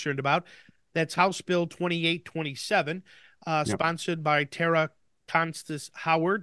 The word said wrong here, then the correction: Costa: Did I say that right? – Costa Howard.